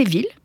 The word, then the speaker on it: ville: Famille, gorille, anguille, aiguille, camomille, en fait, presque tous les mots qui se terminent en I2LE se prononcent comme fille. Il n'y a que trois mots, en fait, qui fassent exception à la règle. C'est ville, tranquille, et mille, le nombre, hein, 10 × 100.